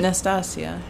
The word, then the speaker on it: Nastasia: Nastasia. – Yeah, I say Nastasha, and some people. – It's one syllable less.